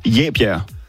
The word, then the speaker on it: Jebjerg: Det udtales Jebjerg. Ikke med E, men med A.